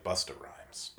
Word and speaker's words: Busta Rhymes: And I like Buster Rhymes. – Or, excuse me, I like Busta Rhymes. Mm-hmm. Gotta say his name right.